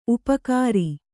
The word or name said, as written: ಉಪಕಾರ